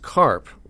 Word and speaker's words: Karpf: Just a note, it's pronounced carp, like the fish, but it's spelled K-A-R-P-F. So there's a silent F on the end.